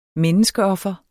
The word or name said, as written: menneskeoffer